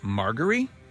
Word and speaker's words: Margaery: I believe it's pronounced Marjorie.